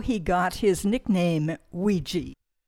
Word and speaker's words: Weegee: So Ouija, which is O-U-I-G-A for the Ouija board, he took that phonetically and recast it as Ouija, W-E-E-G-E-E, and that's the name he used for the rest of his career.